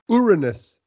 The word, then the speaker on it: Uranus: Uranus, I believe is the proper pronunciation.